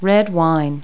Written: red wine

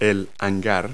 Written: hanger

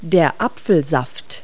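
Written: apple juice

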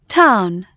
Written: town

